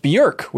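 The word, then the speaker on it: Björk: that it's pronounced Bjork, which blew like my mind. Jerk, like jerk yeah, but but on that he talked a little bit about composing Halo music, and he had some.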